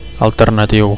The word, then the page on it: alternatiu: Catalan